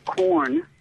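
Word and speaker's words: Quorn: It's by a company made Q-U-O-R-N. I think they pronounce it corn.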